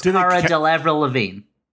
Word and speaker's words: Cara Delevingne: Do you think the casting was like, my guess is that they cast, what, Cara Delevingne, or how do you pronounce her name? – Yeah, I've got no idea. – It's Cara Delevingne.